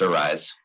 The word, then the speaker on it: Aryse: But some people mispronounce it. So it is pronounced Arise. It is spelled A-R-Y-S-E.